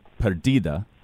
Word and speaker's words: Perdida: So the album's called Perdida. I'm saying that right, right? – Yeah, okay. Absolutely.